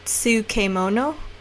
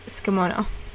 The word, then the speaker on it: tsukemono: And if you look at the word and you're trying to sound it out, you'll probably say something more like tsukemono, but it's actually pronounced tsukemono.